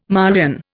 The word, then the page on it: Marian: Indian